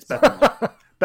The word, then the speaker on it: Beppo: I thought it was Buca di Beppo. – Oh, sorry, mispronunciation. Yeah, it's Beppo.